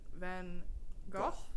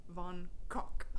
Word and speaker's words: van Gogh: But van Gogh, is not correct. It would actually be pronounced van Kok.